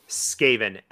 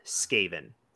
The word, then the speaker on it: Skaven: Skaven. S-K-A-V-E-N. Skaven.